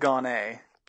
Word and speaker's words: Gonnet: So it's spelled Gonnet, pronounced Gonnet, and you can obviously imagine, everyone assumes that because it's spelled French, that I'm French Canadian because everyone thinks, oh, French Canadian, you're from Canada.